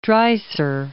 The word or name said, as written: Dreiser